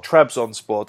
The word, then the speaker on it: Trabzonspor: At, is it Trazon? – Trabzonspor. – As Lee will tell you, I'm awful at pronouncing things.